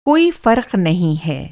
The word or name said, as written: फ़र्क़